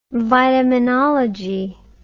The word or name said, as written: vitaminology